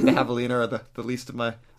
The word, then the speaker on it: Javelina: Javelina 100? – Yeah, with the silent J for both.